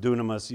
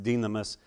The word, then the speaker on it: dynamis: In the Greek, it's the word dynamis, or it can be pronounced dunamis. Usually it is by people, but it should be pronounced dynamis.